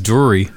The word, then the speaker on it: Dury: But Ian Drury, or Dury, how do you pronounce it? – I think it's Dury.